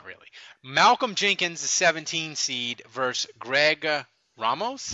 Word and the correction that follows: Romeus: How do you say that? – Romeus. – Greg Romeus.